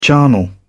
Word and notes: charnel house: British English

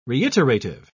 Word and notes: reiterative: British English

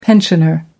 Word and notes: pensioner: American English

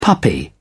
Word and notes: puppy: British English

